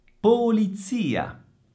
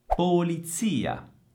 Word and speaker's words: polizia: Polizia, polizia. Attenzione all'accento e attenzione al suono della Z. È la stessa Z di pizza e pur essendo una sola Z, al suono sembra quasi una doppia.